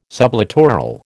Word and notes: sublittoral: American English

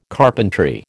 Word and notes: carpentry: American English

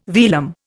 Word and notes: velum: Indian

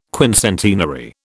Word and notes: quincentenary: British English

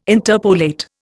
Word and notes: interpolate: Indian

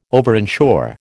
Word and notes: overinsure: American English

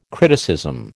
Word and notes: criticism: American English

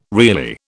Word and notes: really: British English

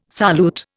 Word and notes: solute: Indian

